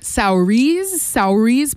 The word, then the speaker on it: Saoirse: It's spelled S-A-O-I-R-S-E. I was like, Saoirse, but no, it's actually Saoirse.